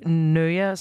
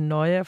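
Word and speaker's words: Nøia: Altså, det vil sige Nøja, som jeg udtalte Nøja, fordi man kan ikke lave Ø på Instagram, så I hedder N-O-I-A på Insta, men det staves jo N-Ø-I-A.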